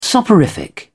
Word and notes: soporific: British English

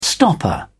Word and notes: stopper: British English

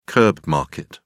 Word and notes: curb market: British English

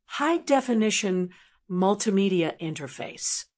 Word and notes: high definition multimedia interface: American English

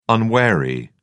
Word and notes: unwary: British English

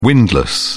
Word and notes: windless: British English

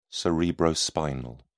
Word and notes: cerebrospinal: British English

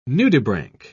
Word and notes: nudibranch: British English

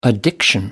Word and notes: addiction: British English

